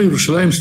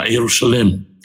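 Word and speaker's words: Иерушалаем (ירושלים): Почему Иерушалаем стоит в двойственном числе? Это южное произношение. Если вы посмотрите, в Торе в большинстве случаев э, написано Иерушалем.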